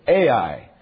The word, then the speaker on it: Ai: And however you have grown up pronouncing this big city with two letters, you will have to bear the Cambridge University way of doing it, and that makes me sound very learned, as we will pronounce it Ai, and I know a few others can pronounce it that way.